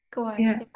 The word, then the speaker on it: Kauai: it's Kauai? Is that how it's pronounced? I feel like I don't have, yeah, Kauai.